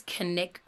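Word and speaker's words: Knik: Robert's favorite spot tended to be along the, I believe it's Kinnick River. It's K N I K, but yeah. I looked up the pronunciation. I'm pretty sure it's the Kinnick River.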